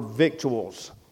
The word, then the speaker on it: victuals: That word victuals is really, that's where we, you ever heard of eat your victuals? That's the correct pronunciation of that. But it's spelled V-I-C-T-U-A-L-S. And I read that in front of a bunch of preachers, and they laughed at me. They said, "That's victuals." I said, "Well, it ain't spelled like victuals."